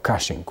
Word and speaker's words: Cushing: Acesta este sindromul Cushing, sau Cushing, cum mai pronunță unii.